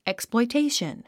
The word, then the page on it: exploitation: èksplɔitéiʃən; エクスプロイテイション